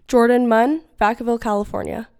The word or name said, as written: Jordan Munn